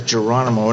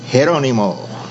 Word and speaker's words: Geronimo: Actually, everybody says Geronimo, and everyone forgets that G should be an H. Mm. And it's a Spanish name. It's not an Indian name. And it's actually pronounced Geronimo.